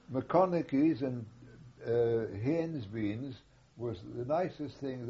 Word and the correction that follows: Heinz: How do you spell it? – Well, it's German, Heinz, you know.